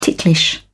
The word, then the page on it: ticklish: British English